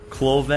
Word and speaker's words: Klavay: But Clove is how it says. I could be butchering that, but I think it's Clove. And it's spelled K-L-A-V-A-Y. But he says this, say it like Clove.